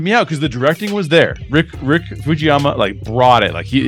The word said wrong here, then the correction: Famuyiwa: How do you pronounce his name, by the way? I always uh, it. It's Rick Famuyiwa. Famuyiwa. That's way better.